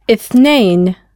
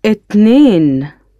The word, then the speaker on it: اثنين: اثنين. – اثنين.